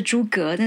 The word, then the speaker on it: 诸葛: 金城武也是在我上一集啊，就很最近的星期一的那一集，呃，讲的是诸葛亮、诸葛孔明哈，呃，正统的发音就是诸葛，但是字念二声，你可以听一下哦。